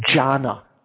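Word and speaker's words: jhāna: In Pali, that the word dhyana is pronounced jhana.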